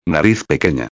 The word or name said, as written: pequena